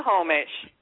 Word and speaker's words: Snohomish: Nahoma or Nahoma or however you say that county. – Snohomish. – It's not spelled Nahomish. It's spelled... – No, Snohomish. Snohomish. – Snuh.